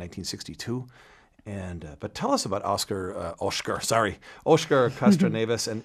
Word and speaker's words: Oscar Castro Neves: Did I pronounce his name correctly? He's an important guy. – Depending if you're from Rio or or São Paulo, they tell me. But yes, Oscar Castro Neves is kind of the more American. You were right.